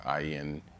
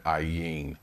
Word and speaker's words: Ayin: And that means 74, Ayin meaning, it's a silent letter.